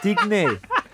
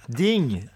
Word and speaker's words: Digne: No, yeah, it's not Digne. – What is it? – Ding. It's not. There's no accent on the E.